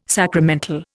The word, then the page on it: sacramental: Indian